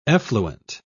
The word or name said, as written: effluent